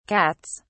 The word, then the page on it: cats: British English